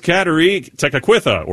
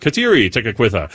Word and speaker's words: Kateri Tekakwitha: It's also today the memorials of Saint Bonaventure and Saint Kateri Tekakwitha, or Saint Kateri Tekakwitha, or Saint Catherine Tekakwitha. You get to choose. It's like Baskin Robbins. You get to pick your flavor of how you want to pronounce Saint Kateri's name.